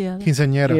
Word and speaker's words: Quinceañera: Quinceañera.